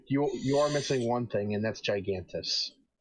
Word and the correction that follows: Gargantus: Gargantus. – I've been saying it wrong this entire time.